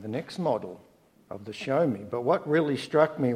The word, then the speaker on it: Xiaomi: I bought this flash Chinese phone, and according to Phoebe, it's called a Xiaomi. It's, if we spell it in English, it's X-I-A-O-M-I. Is it Xiaomi? That'll do.